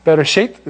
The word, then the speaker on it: Bereshit: So bere, and that's a sh. You pronounce that shi. Okay, there's the yod right there. Shi, sheit. Bereshit is how you'd say it.